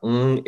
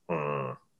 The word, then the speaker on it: Ng: And ng is actually more accurately pronounced as ng. I don't even know if you can hear it on Zoom, but it is the lowest of 9 tones.